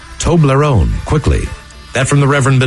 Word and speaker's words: Toblerone: Toblerone.